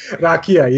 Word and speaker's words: rakia: I think I'm most excited about the Radka at Belgrade. – Rakia, yes. – Rakia, yes. I always say it wrong, and for those of you that don't know what that is, it's basically, as Dan described it to me, Serbian moonshine.